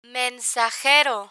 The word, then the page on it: mensajero: Spanish